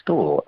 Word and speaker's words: Storr: General Storp. My first name is Storp, which is spelled S-T-O-R-R.